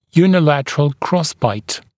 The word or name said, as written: unilateral crossbite